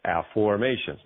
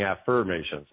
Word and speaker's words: Afformations: Well, actually, that is one of the missing pieces that I discovered is my discovery of Afformations. Now, for the folks listening, I'm not saying it wrong. I'm not saying affirmations. I'm saying a new word, unless you've heard of me or heard of my work before.